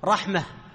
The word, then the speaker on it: رحمة: وإذا وقفت عليها تقول رحمة. لماذا وقفت بالهاء ولم تقف بالتاء؟ لأن القاعدة تقول أن النطق يتبع الكتابة، فأنت تكتبها هاء أم تاء؟ هاء، هي تكتب ترسم ماذا؟ كرسم الهاء، واضح هذا؟ فلهذا عند الوقف عليها تنطق هاء.